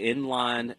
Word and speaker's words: Enlign: They're based out of Raleigh, and it's called Inline. It's spelled E-N-L-I-G-N, Inline mm-hmm.